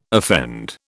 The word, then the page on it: offend: British English